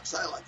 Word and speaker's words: Psylocke: How do you say her name? – Psylocke? – Psylocke, that's right. I never say it right either.